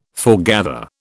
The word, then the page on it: foregather: British English